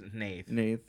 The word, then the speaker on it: Hnath: He's pronounced it Nath.